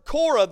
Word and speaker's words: Korah: Now, the word Korah there is spelled C-O-R-E in the New Testament. In the Old Testament, it's spelled C-O-R-A-H.